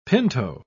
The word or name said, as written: pinto